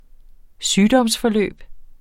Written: sygdomsforløb

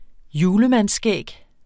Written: julemandsskæg

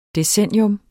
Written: DECENNIET

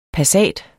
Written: passat

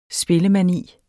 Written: spillemani

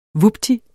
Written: vupti

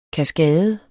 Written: kaskade

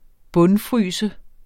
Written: bundfryse

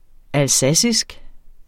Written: alsacisk

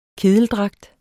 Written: kedeldragt

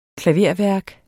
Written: klaverværk

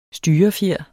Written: styrefjer

